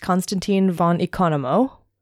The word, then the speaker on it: Konstantin von Economo: After seeing several of these patients, neurologist Konstantin von Economo, let's hope that's how you say it. – Yep.